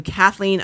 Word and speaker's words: Cathlene: If you don't follow me on Instagram yet and you are listening, please go to Kathleen underscore Miner. That is spelled C-A-T-H-L-E-N-E underscore M-I-N-E-R.